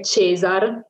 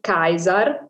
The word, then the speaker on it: Caesar: E quindi in Italia siamo abituati a dire Cesar, per esempio, Cesare, invece di Caesar, e dopo vi spiegherò la differenza, perché appunto la tradizione italiana ha portato a questo tipo di pronuncia.